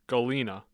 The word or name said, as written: Galena